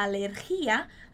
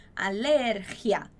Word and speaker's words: alergia: Muito cuidado com essa pronúncia dessa palavra, né? A gente não vai falar alergia, a gente não tem nenhum acento ali no ri. Então, a pronúncia dessa palavra é alergia, ok?